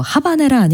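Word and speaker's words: Habanera: 소프라노 마리아 칼라스의 노래와 조르주 프라트르가 지휘하는 프랑스 국립방송 교향악단의 연주였는데 게시판에서 하바네라 아닌가요? 이렇게 물어보셨는데 하바네라로 잘 알려져 있죠, 사실은. 근데 이제 프랑스어 발음이 H가 묵음이라고 해서 아바네즈, 뭐 하바네즈라고도 그냥 통상적으로는 저희끼린 다 알아들으니까 큰 문제 없지 않을까.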